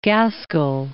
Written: Gaskell

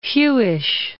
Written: Hewish

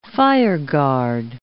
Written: fireguard

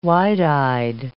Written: wide-eyed

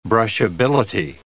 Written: brushability

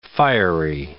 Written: fiery

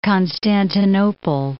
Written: Constantinople

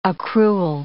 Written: accrual

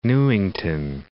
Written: Newington

